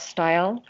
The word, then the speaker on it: Steil: Style, on Instagram, and I'm on Facebook.